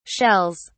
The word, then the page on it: shells: British English